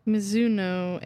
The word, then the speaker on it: Mizuno: Mizuno. M-I-Z-U-N-O.